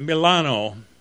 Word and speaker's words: Milano: We set out to visit the cathedral and the one at Milano. He's using the Italian pronunciation for Milan.